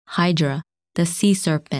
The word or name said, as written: Hydra